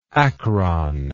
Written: acronal

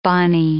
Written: bonny

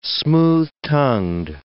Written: smooth-tongued

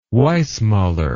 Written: Weissmuller

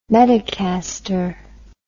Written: medicaster

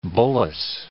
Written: bullace

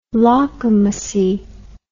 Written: lawkamussy

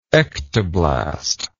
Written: ectoblast

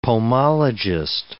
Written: pomologist

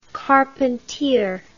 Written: carpentier

